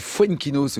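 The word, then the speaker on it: Foenkinos: Déjà, on va dès le début, tout de suite, enlever une vanne que j'ai subie hier, honteusement, parce que j'ai dit Fuenquinos, et vous avez dit, mais non, ça se prononce pas comme ça, les garçons. – Tu le dis parfaitement. – Bah voilà, c'est la bonne prononciation.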